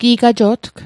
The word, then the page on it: gi'gajo'tg: Mi'gmaq